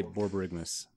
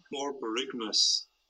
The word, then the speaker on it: Borborygmus: Is, this is how you say Borborygmus. – Borbogamos? – Polyphemus. Nobody. – Polymerization? – Polygraph. – Serendipitous. – Borborygmus. Borborygmus.